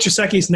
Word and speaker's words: Gesicki: I say Gesicki. I don't know. Isn't that so weird? You read a name, like I've read Mike Gesicki's name hundreds of times, and I'm still not positive. – He says it's Gesicki.